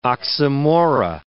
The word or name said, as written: oxymora